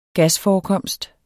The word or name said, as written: gasforekomst